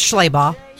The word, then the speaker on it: Schlabach: So Mark Schlabach, that's how you say it, isn't it? Schlabach? – It's Schlabach.